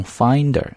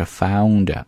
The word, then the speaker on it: founder: 双元音对于中国同学来读不难，但是呢在双元音之后加一个 N, 啊，加一个 N 的这个声音就比较困难了，所以呢一定不要把它读成 finder, 啊，应该是 founder, ow, found, founder,Okay, you can try one more time.